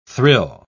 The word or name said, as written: thrill